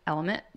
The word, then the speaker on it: LMNT: We love the company Element. It's spelled L-M-N-T.